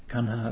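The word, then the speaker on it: Kaṇha: Kanha, of course, as I've mentioned, being the Pali pronunciation of Krishna.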